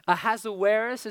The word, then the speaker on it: Ahasuerus: He has a Hebrew name that you're going to enjoy pronouncing this whole time that we're together. Ahasuerus is how we're going to pronounce it. It'll probably come out different every time.